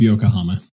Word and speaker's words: Yokohama: Yokohama? I'm mispronouncing it. The hero of Yokohama. No, that's right. – Hero of Yokohama. Yep.